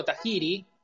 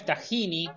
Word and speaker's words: Tajiri: Bueno, volviendo a nuestro amigo Tajiri, que no es Tajini, que es lo que lleva el humus, es Tajiri.